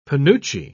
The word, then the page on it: penuche: British English